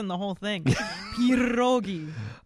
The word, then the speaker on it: pierogi: They're not pierogi. They're pronounced pierogi. – Okay. – You can, pierogi. – If you can roll the R, it helps.